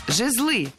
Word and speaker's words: жезлы: Да, это всякие жезлы. Да, и отсюда же скипетр, на самом деле. – Жезлы. Неверно. Правильно, жезлы. Ударение в этом слове ставим всегда на первый слог.